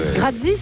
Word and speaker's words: grazie: Grazie, ça se prononce comme ça, grazie.